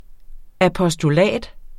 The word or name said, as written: apostolat